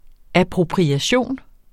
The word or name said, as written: appropriation